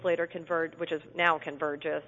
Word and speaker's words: Convergys: And I understand you began your career at, help me pronounce this, Convergys? – Yes, yeah. Convergys.